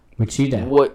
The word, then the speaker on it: Wichita: They didn't know how to pronounce it. I heard a lot of Wichita. – Wichita was my favorite one, but a lot of Wichita, you know, whatever. Like, they could not figure out how to say the name of the city.